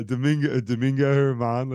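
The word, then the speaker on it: Domingo Germán: No, Domingo Herman. Let's say it right. Right. – Herman. Right. Domingo Herman.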